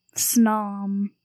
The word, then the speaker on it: Snom: Snom.